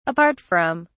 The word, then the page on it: apart from: British English